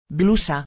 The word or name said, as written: blusa